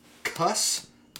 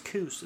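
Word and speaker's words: Cus: His boxing talent was discovered, and he flourished under the tutelage of legendary trainer Cuss D'Amato. He turns pro in 85. – Wait, did the man cuss a lot? – Absolutely. Like it's a sailor. – It's actually pronounced Coos. It's French.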